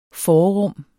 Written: forrum

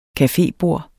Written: cafebord

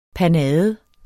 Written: panade

